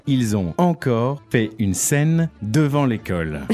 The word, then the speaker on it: scène: That's not the word, it's a scene, scène. – Right. – Une scène.